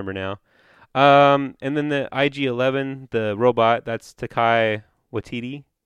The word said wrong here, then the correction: Taika Waititi: Taika Waititi? – Taika. I never knew how to say his name. Thank you for saying it correctly.